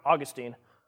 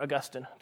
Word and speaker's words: Augustine: It wasn't until a couple hundred years into the history of the church, mostly I would argue from the influences of Augustine, or Augustine, depending on how you like to pronounce it, that amillennialism became a view that was widely accepted.